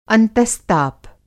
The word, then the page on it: अंतस्ताप: Hindi